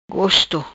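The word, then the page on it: Gosto: patua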